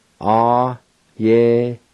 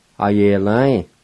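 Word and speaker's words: ayelvi: Number three is body, a-ye-la-in, a-ye-la-in.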